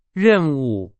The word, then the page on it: 任务: Chinese